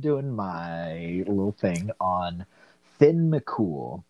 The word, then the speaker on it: Finn McCool: Like I think the most traditional way is it's more like Thean Mac Kumhale is how it's spelled. Like if I was to say it phonetically how it's spelled, but it should be pronounced Finn McCool.